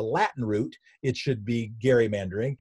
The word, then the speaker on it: gerrymandering: I also pronounce it gerrymandering instead of gerrymandering.